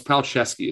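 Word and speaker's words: Palczewski: Alex Pelzewski at Illinois has 52 starts coming back. If he starts presumably all 12 of their games this year, it's Pelzewski is the pronunciation. I apologize.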